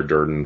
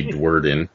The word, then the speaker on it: Durden: You said it's pronounced like Tyler Durden from Fight Club. People always say Dwerden.